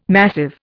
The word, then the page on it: massive: Indian